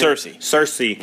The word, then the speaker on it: Cersei: Xerxes, is how you say her name? – Cersei.